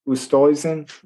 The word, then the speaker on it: Oosthuizen: Oosthuizen. – Oosthuizen.